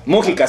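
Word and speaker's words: Múgica: Mujica, Mujica, se dice Mujica. – Acento en la U.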